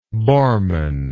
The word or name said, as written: Borman